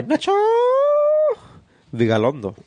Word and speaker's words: Nacho Vigalondo: Directed by Nacho Vigalondo.